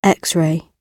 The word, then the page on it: X-ray: British English